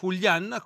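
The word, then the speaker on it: Julián: E oggi, come 4 anni fa, Julian, con l'accento doverosamente sulla A,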